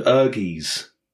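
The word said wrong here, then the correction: Ehrgeiz: Air Guys.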